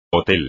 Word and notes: hotel: espagnol

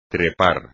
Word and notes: trepar: espagnol

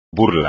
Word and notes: burla: espagnol

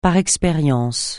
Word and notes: par expérience: francais

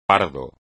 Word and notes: pardo: espagnol

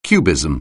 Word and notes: cubism: anglais

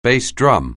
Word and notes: bass drum: anglais

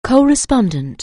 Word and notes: co-respondent: anglais

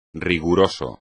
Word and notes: riguroso: espagnol

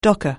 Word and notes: docker: anglais

